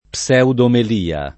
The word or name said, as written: pseudomelia